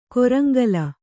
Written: ಕೊರಂಗಲ

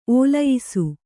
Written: ಒಲಿಯಿಸು

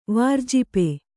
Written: ವಾರ್ಜಿಪೆ

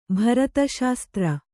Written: ಭರತಶಾಸ್ತ್ರ

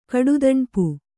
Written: ಕಡುದಣ್ಪು

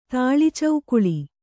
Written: ತಾಳಿಚೌಕುಳಿ

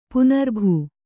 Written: ಪುನರ್ಭೂ

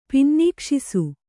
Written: ಪಿನ್ನೀಕ್ಷಿಸು

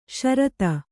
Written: ಶರತ

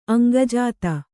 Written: ಅಂಗಜಾತ

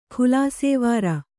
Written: ಖುಲಾಸೇವಾರ

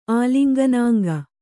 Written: ಆಲಿಂಗನಾಂಗ

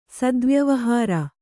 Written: ಸದ್ವ್ಯವಹಾರ

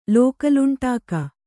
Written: ಲೋಕಲುಂಟಾಕ

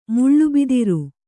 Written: ಮುಳ್ಳುಬಿದಿರು

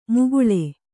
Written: ಮುಗುಳೆ

